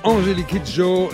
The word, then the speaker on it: Angélique Kidjo: C'était Angélique Kidjo, La vida es un carnaval.